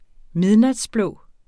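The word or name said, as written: midnatsblå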